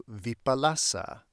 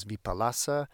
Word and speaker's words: vipalasa: But it would seem that that line of the Heart Sutra regarding escaping inverted thinking is a reference to vipalasa, as it is known in Pali. In Sanskrit, this idea is viparyasa or viparaya. There's sort of different pronunciations, but the Pali, again, is vipalasa, and it means inverted, like upside down, like turned literally upside down.